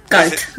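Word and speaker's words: Guld: En katakana se escribe Garudo. Garudo. – Galt. Entonces sí, Galt. Garudo.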